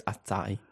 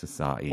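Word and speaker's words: açaí: Acai, I believe the acai berry is a superfood. – I said acai. – Oh, did you? – Achai. But I'm sure it's acai. – It's acai.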